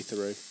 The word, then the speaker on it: Theroux: I love how Americans say Louis Theroux. – Is it pronounced Theroux? – Yeah. – Oh. – As far as I know. – Oh, good to know. – I might be wrong, though. I might just look like an idiot. – I feel like he pronounces it through. I think you're right.